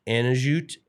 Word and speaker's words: Anujit: His name was Anujit, A-N-U-J-I-T, Anujit. And what do you think? – Anujit? – Yeah, maybe Anujit? You don't pronounce the J, but the last name is Hir Unra Ten Tanat Nakorn.